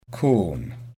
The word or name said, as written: corn